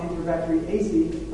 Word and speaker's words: Enterobacteriaceae: That family ending is the ACEAE, so it's a suffix, and we saw Enterobacteriaceae pronounced AC, even though it's spelled ACEAE.